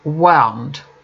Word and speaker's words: wound: Note that there is a verb, wound, which rhymes with sound.